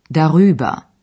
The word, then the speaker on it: darüber: Następny przykład dotyczy przyimka über, czyli darüber. Dodane w środku r ułatwia wymowę.